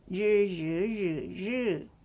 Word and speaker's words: 日: 日西, the word 日 has R as a consonant, it's pronounced as 日, and with the vowel I, I usually it sounds E, right? But after R, I should be sounded as U sound. So 日 U, 日, with the fourth tone, 日日日日.